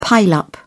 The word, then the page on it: pile up: British English